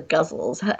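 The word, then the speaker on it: ghazal: I don't know, is it ghazals or guzzles? How do you say that word? You know, the form I'm talking about. – Everyone says guzzle, who seems like they know what they're saying, but I always say gazelle.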